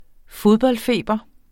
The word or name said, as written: fodboldfeber